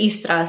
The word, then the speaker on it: Istra: In Croatia, we pronounce it Istra, so you drop the second I.